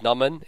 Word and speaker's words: Naman: It's spelled N-A-M-A-N. – He's the only one who says it like that, by the way. Everyone else says Naman. – Well, my family says that. – They're the only ones who can say it right.